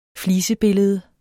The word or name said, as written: flisebillede